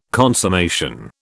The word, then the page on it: consummation: British English